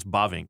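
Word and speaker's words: Bavinck: It's Bovink.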